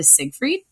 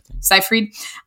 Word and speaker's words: Seyfried: And this stars Amanda Seyfried, or Seyfried, I'm saying her name incorrectly. Seyfried.